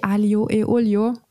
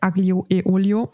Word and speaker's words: aglio e olio: Aber ich hatte den Tag davor, ich habe es jetzt hier im Podcast richtig aussprechen gelernt, Spaghetti aglio e olio. Oder wie ich damals sagte, Spaghetti aglio e olio.